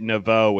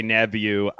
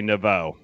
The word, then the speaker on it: Neveau: But when people get it right, they usually get Naveau, which is why I went with that over our more traditional pronunciation, which is Nebu. – Oh, I did not know that. – Yeah, if you talk to my grandparents, they say Nebu, I say Naveau.